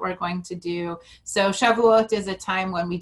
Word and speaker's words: Shavuot: And I believe, I'm probably going to say it wrong, Shavuot.